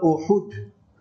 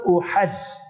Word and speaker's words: Uhud: The 7th of Shawwal and the 3rd year after the Hijrah, the Battle of Uhud, not Uhad. Learn to pronounce the words correctly, not Gaza, Gaza, Al-Mubarakah. So Uhud.